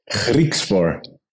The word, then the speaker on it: Griekspoor: Griekspoor. – Griekspoor. – That's good. That sounds good. Okay. I know it's not easy, especially with the G. We pronounce it on a different way here in the Netherlands, but no, that's not bad at all.